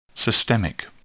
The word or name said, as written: systemic